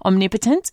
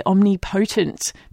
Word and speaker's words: omnipotent: I often mispronounce omnipotent and I say omnipotent because that's exactly how it is written.